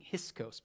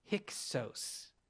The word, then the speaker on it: Hyksos: I believe last week I was mispronouncing that. I think I was saying Hyksos, but it's hard to say. Hyksos.